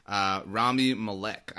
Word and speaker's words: Rami Malek: Rami Malek, I think I'm pronouncing that right. – Yeah, that's right.